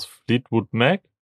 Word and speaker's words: Fleetwood Mac: Äh, Little Lies von, wie spricht man die nochmal aus? Fleetwood Mac? – Ja.